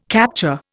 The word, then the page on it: capture: Indian